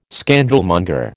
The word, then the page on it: scandalmonger: American English